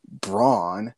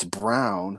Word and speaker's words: Braun: There's Christian Brown, but it's spelled Braun, which it's like Braun, but it's Brown.